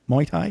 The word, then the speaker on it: Muay Thai: Muay Thai? – Yeah.